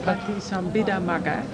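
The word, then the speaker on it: Paṭisambhidāmagga: In the Patisambhidamagga, how do you pronounce that? – Patisambhidamagga.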